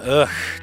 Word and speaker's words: UGHH: We welcome UGHH to the show tonight in our second hour. UGHH is the spelling, and according to canon, it's just pronounced UGH.